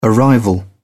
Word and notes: arrival: British English

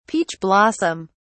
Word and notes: peach blossom: British English

